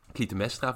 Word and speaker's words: Clytemnestra: En Clytemnestra, volgens mij, zeg ik dat goed? – Ja.